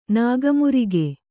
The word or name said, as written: ನಾಗಮುರಿಗೆ